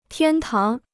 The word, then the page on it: 天堂: tiān táng